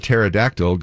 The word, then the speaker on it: pterodactyl: Why can't you hear a pterodactyl going number one? – Because the P is silent.